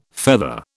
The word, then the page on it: feather: British English